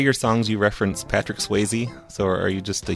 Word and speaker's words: Swayze: Yeah, Patrick Swayze. First of all, it rhymes with baby. And it rhymes with baby, it rhymes with crazy, it rhymes with daily, on the daily.